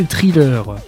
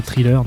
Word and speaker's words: thriller: Alors, si c'est des thrillers, ça peut passer. Alors, je prononce bien la française, hein, tu vois, thriller, normalement c'est pas comme ça, hein, c'est THR en anglais, donc c'est thriller.